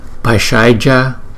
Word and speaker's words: Bhaisajya: Bhaisajya, Bhaisajya Guru, Bhaisajya Guru. Rồi, ở đây chữ D có dấu nặng thì chúng ta đọc là D, D. Thì giống như chữ D nhưng mà cái âm á thì trên vòm họng chúng ta đưa ra, nó giống như chữ S có dấu nặng vậy đó.